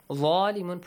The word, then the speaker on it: ظالمون: ظالمون, pronunciation of the letter ظَا with an A sound.